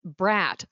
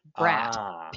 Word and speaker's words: Bradt: It's Bratt. The name is Bradt, but you pronounce it like there's no D. Bratt. People try to be friendly and put an N in there and all this kind of stuff and going, nah, really, it's Bratt.